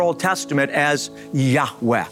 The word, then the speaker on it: Yahweh: feel is pronounced a breathy Yahweh.